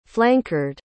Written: flankard

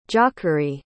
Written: jacory

